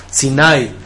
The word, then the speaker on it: Sinaí: En hebreo el acento no es en la I, en el hebreo es el acento en la A, Sinaí.